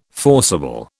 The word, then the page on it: forcible: British English